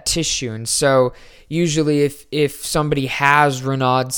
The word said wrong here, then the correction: Raynaud: syndrome or Raynaud's syndrome, they're saying you pronounce it Raynaud Raynaud's syndrome.